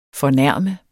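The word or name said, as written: FORNÆRMES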